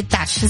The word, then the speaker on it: Taj: Si lo quiere buscar en el Google Maps o en su aplicación de mapas, le pone Grand y le pone Tash. Se escribe T-A-J.